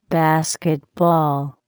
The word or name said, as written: basketball